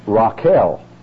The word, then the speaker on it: Rachel: Comes out like this, R-A-C-H-E-L, pronounced Raquel. But we'll call Rachel Rachel, not Raquel.